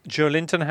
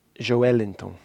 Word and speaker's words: Joelinton: Joelinton, how should you pronounce his name? – Joelinton. – Joelinton. – Yeah, the emphasis is on the L. – Joelinton. – Joelinton. So people are making that joke saying, oh, he's Joe Linton. But more accurate would be... – Joelinton.